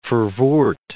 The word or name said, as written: Verwoerd